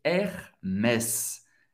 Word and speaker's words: Hermès: Hermès, em francês, Hermès. Hermès. duas sílabas, er, mes. ok? O S vai ser pronunciado no final da palavra. E tem o som do S mesmo.